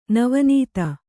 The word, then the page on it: ನವೀನತೆ: navīnate